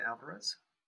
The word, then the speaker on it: Alvarez: Fede Alvarez.